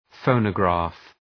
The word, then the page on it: phonograph: ‘fəʋnə,græf